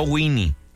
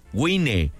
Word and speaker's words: Baja Wine: Vaya a su página de Facebook, Baja, se escribe Baja Wine, no Winnie, Wine, Baja Wine.